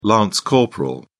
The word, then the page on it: lance corporal: British English